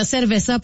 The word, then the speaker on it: cerveza: Puis là, il y a quelqu'un qui a dit au 6-12-12, faut que les Québécois arrêtent de prononcer cerveza avec un V, même si ça se lit comme ça, ça s'écrit comme ça. Mais le V en espagnol se prononce comme un B.